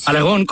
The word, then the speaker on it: Aragón: Aragón con acento en la O.